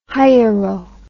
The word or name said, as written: hiero